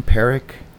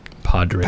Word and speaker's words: Podrick: And that moment when, how do you say his name? Perrick? – Podrick. – Perrick? – Podrick. – Yeah, P-A-D. – Yeah, but they say it. They don't say the D.